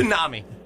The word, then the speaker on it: tsunami: Honestly, it's a trick question because the T throws everybody off. – I know. – That's right. It makes no sense to spell that. – It makes no sense. It's a tsunami.